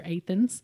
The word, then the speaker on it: Athens: And it is at Jacobson Park, which is a big park in Lexington, right off of exit 104, the Athens Boonesboro, and that is how you say it around here, Athens.